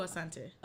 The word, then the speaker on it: Asante: Coco Asante. – Asante. Okay. – Mm-hmm. Mm-hmm. – Because, girl, I don't know what I be saying. I say it the black way. Asante, like Ashanti.